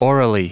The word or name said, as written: aurally